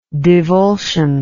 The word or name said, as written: divulsion